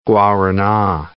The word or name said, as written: guarana